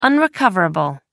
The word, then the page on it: unrecoverable: American English